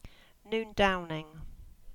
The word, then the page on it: Noondowning: English